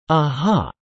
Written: ah-ha

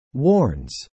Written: warns

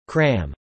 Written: cram

